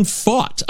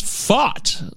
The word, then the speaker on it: Fought: Apparently, it is Brandon Fought. I never looked this up, but the pronunciation says Fought. Like, you want to fight. We fought. We had a great...